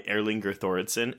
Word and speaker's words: Erlingur Thoroddsen: So we are talking Midnight Kiss, directed by, as we said, Carter Smith, written by Erlinger Thoradsen, and I hope I didn't butcher that name. I think I said it right. – Sounded pretty good.